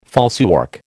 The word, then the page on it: falsework: American English